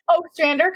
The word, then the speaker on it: Ostrander: Ostrander, kind of like O'Malley, but without okay. The little comma.